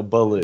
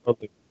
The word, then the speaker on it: Belew: It's just like the bear from the Jungle Book. So, Baloo. – Baloo, okay. I always said, like, belly. – Yeah, Baloo.